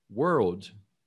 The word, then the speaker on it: world: Você vai falar were, were, e depois old, world.